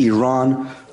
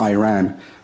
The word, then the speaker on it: Iran: You're pronouncing our country's name wrong. Our country is named Iran, not Iran.